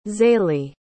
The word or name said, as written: Zalee